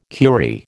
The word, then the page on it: curie: American English